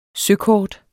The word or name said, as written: søkort